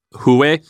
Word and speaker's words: Huawei: Is it called the Hawaii? How do you pronounce that? Huawei. – Like that.